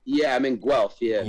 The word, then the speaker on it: Guelph: It's like sounds like you're puking. It's like well, you know, it's like Ralph. It's spelled a G U E L P H. – Guelph.